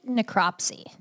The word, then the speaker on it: necropsy: Uh, they had to have a second necro nec, nec, how do you pronounce that? – I think necropsy.